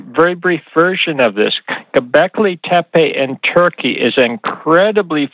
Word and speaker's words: Göbekli Tepe: And obviously, Göbekli Tepe. No, I totally pronounced that wrong. But Göbekli Tepe. Göbekli Tepe in Turkey.